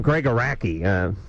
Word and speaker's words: Gregg Araki: Now you got his name right. You pronounced it right tonight. – Well, he's here. – Yeah. – Well, I called him Gregg Araki. – 400 times. – Well, I don't know. Listen, it's A-R-A-K-I.